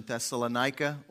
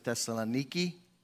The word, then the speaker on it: Thessaloniki: Thessalonica, or I think the Greeks pronounce it Thessaloniki.